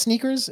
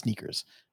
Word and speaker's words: SNKRS: Is it pronounced Sneakers? Is that? – Yeah, it's Sneakers. Okay. It's Sneakers. – Okay. All right. Because it's so for folks who are interested, it's the SNKRS app, but it's pronounced Sneakers.